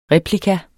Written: replika

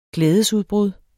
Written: glædesudbrud

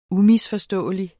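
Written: UMISFORSTÅELIG

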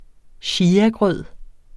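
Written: chiagrød

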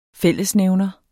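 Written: FÆLLESNÆVNER

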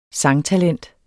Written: sangtalent

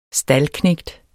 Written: staldknægt

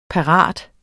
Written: parat

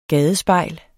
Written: gadespejl

